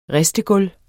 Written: ristgulv

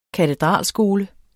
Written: katedralskole

